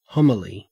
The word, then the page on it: homily: Australian English